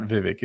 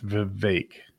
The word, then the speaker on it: Vivek: It's not Vivek. It's Vivek. Rhymes with cake.